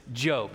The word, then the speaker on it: Job: Job, if you are opening your Bible and looking, it's the book that looks like Job, only it's not Job. You pronounce this Job.